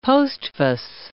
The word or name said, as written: postface